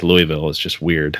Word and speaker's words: Louisville: We have Josh Thompson, who is currently the director of football operations at Louisville. Am I saying that correctly? I know there's... – You got that correct.